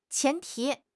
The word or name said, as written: 前提